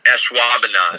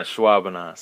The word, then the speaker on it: Ashwaubenon: How do you pronounce it? – Most people don't. It's Ashwaubenon. – Ashwaubenon.